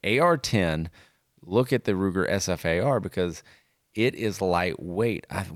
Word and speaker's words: SFAR: All right, this is brought to you by Ruger and the Ruger SFAR. You do not pronounce it Safar or Safar. It's really good, but it's SFAR, and it is a 7.62-308 gun, 10+20 capacity, Magpul stock, threaded.